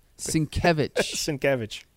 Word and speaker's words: Sienkiewicz: Sienkiewicz.